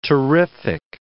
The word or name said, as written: terrific